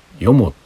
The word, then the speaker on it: 四方: よも」って店があるんですよ。あの四方って書いて「よも」って読む店があるんですけどね酒屋。